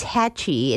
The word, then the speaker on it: Haatchi: Wendy Holden, Holden, and the book is Hatchie. It's spelled Haatchi and Little Bee, the inspiring true story of one boy and his dog.